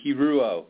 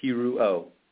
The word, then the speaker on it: Hiroo: It's Jason Hiroo. You're really close. Three syllables. Hiroo.